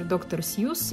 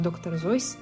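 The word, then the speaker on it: Dr. Seuss: Ну, на возраст Кормака а, вспоминается, конечно же, местный мегапопулярный автор доктор Сьюз, или, как правильно оказывается, произносить доктор Зойс.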